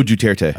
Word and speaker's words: Duterte: Oh, Duterte. – Yes. – Yeah, Duterte. – I always feel like I'm going to really screw it up. – It rolls off the tongue. Yeah, that's... Yeah. – Duterte's boot was up our ass as we were declaring total victory in the Philippines after 50 years of occupation.